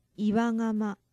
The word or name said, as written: iwa gama